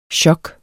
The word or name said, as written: chok